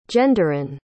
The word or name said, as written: genderine